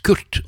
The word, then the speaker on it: QRT: Og det kan jo veldig lett uttales Kurt, og det meningen også.